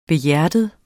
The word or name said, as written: behjertet